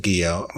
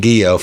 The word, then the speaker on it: Guillot: And I said, I say, well, a lot of people say that, but we but I pronounce it Guillot. My father asked me to pronounce it Guillot a couple years ago. And so I've been saying Guillot for the last, 45 years. So, yeah, it's Guillot.